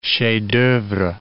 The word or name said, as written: chef d'oeuvre